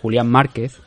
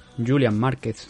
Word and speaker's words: Julián Márquez: la primera parada es la de Julián Márquez, Julian Márquez, si lo pronuncian los norteamericanos, contra Gregory Rodríguez en 185 libras, división middleweight.